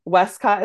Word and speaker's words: Wescott: Wescott is spelled WESCOTT.